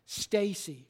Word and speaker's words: Stace: You pronounce his last name S-T-A-C-E, Stacy, all right?